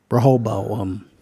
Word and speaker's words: Rehoboam: Rehobo? – Rehobo. Rehoboam. – There you go.